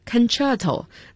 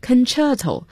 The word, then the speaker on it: Concerto: Concerto, 大家注意一下 C 字母的发音 Concerto 代表协奏曲，应该是很多种乐器在一起演奏吧。